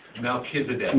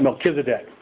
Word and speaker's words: Melchizedek: Melchizedek. Melchizedek.